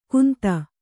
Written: ಕುಂದ

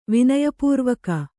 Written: ವಿನಯಪೂರ್ವಕ

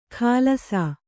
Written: ಖಾಲಸಾ

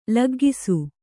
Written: ಲಗ್ಗಿಸು

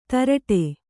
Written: ಧಾರಾಟ

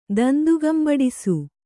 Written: ದಂದುಗಂಬಡಿಸು

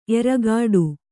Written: ಎರಗಾಡು

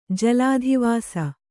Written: ಜಲಾಧಿವಾಸ

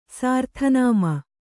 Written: ಸಾರ್ಥನಾಮ

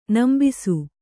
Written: ನಂಬಿಸು

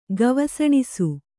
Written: ಗವಸಣಿಸು

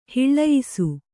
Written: ಹೊಲೆಯಿಸು

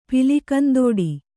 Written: ಪಿಲಿಕಂದೋಡಿ

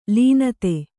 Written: ಲೀನತೆ

